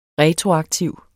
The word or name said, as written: retroaktiv